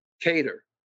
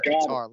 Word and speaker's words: Kattar: Hey, for $50, pronounce Calvin's last name correctly right now. – Kattar. – I am sending you that $50 because you said guitar last time.